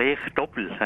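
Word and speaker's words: Rehetobel: In Rehe-Dobbel, habe ich es richtig ausgesprochen? – Also, Rehe-Dobbel hat man gesagt. – Rehe-Dobbel? – Rehe-Dobbel hat man einmal gesagt.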